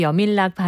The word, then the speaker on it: 여민락: 여밀락 발음은 여민락이 아니고 여밀락입니다.